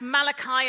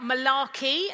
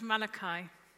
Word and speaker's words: Malachi: That's Malachi and not Malarkey, as someone else joked. It's pronounced Malachi.